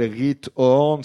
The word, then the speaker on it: Giethoorn: cette ville qui s'appelle Riethoorn, ça s'écrit Giethoorn.